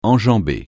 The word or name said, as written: enjambée